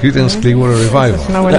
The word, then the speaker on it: Creedence Clearwater Revival: The uh, la, Credence, Credence, Creedence, la Credence Clearwater Revival. Creedence Clearwater Revival.